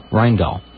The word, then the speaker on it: Rijndael: R-I-J-N-D-A-E-L. Rheindahl.